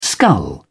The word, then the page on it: skull: British English